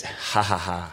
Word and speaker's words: Ja Ja Ja: Yeah, the J is pronounced H in the Mexican language. So it says J-A-J-A-J-A, but it's Ha Ha Ha.